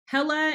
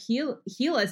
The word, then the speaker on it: Hela: You know, for our listeners, before we started to record, I was like, how do I pronounce it? It's Hela. And she said, no, it's Hela, like Henrietta.